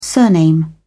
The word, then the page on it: surname: British English